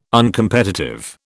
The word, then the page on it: Uncompetitive: British English